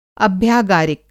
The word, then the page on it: अभ्यागारिक: Hindi